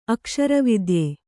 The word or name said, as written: ಅಕ್ಷರವಿದ್ಯೆ